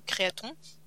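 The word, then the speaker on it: Créathon: Mais regardez, Créaton. ça s'écrit un peu comme marathon, mais Créaton.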